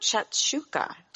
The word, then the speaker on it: shakshuka: Talk about shakshuka. Did I say that right? – Oh, yes, you did.